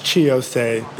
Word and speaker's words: Chi Osei: We have Mr. Shai Osei. Did I pronounce that correctly? – Is he on? – It's Chi Osei. Thank you very much.